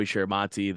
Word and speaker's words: Chiaramonte: My socials for everything are just my name, which is Joey Chiaramonte.